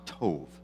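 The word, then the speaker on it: tov (טוב): The Hebrew word for evil is pronounced ra, and the Hebrew word for good is pronounced tov.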